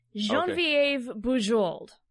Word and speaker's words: Genevieve Bujold: Genevieve Bujold. Is that right? – No. Incorrect. You have to give it the French enunciation, my friend. – Okay. So let me try it again. Genevieve Bujold.